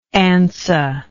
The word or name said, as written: ansa